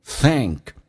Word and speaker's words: think: OK，我们来看一下 think 咬舌，同时后面是一个象鼻音 elephant trunk，是往后一点点，舌身往回缩，舌后端隆起来，开口比较大，嗯， think。